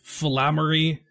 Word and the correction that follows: phylactery: I just said it. It's phylactery.